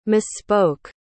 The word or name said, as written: misspoke